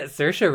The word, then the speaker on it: Saoirse: Saoirse Ronan. – I just wanted to hear you pronounce it. Yeah, Saoirse Ronan.